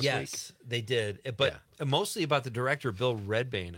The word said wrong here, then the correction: Rebane: I think. Okay, that's how you say it. Rebane.